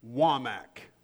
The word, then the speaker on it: Wamack: My last name is Wamack, W-A-M-A-C-K.